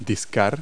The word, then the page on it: dial: verb